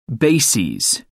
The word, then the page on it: bases: American English